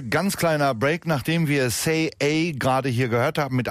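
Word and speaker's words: Sei A: Er spricht sich tatsächlich Say A, schreibt sich aber, und das sollte ich vielleicht dazu sagen, S-E-I und dann Buchstabe A.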